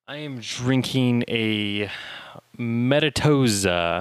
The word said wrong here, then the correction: Metazoa: Am I saying that right? – Nope. – Metazoa. – But you called it Metatoza the first time. – Yeah. – Just Metazoa.